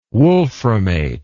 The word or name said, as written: wolframate